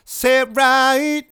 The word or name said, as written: Say It Right